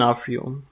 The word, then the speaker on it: D'Onofrio: Vincent, Vincent D'Onofrio. – D'Onofrio. – D'Onofrio. – Ja, ich war halt immer überlegen, ob D'Onofrio oder Dionofrio.